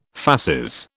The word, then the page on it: fasces: British English